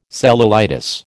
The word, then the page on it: cellulitis: American English